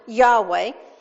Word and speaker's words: Yahweh: but in Hebrew pronounced Yahweh.